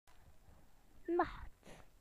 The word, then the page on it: night: British English